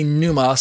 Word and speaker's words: pneuma: It's also of interest that in the Greek scriptures, the Greek word pneuma, or you can say pneuma, some people don't pronounce the P. The Greek word pneuma, I was taught when I learned my Greek, I say the P.